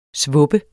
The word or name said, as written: svupper